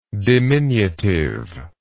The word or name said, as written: diminutiveness